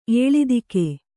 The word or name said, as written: ಏಳಿದಿಕೆ